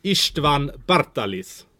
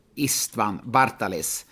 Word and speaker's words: István Bartalis: Ja, det är min ungerska svägerska blir väl arg på mig nu om jag inte säger rätt. István Bartalis. – István Bartalis.